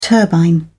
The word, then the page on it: turbine: British English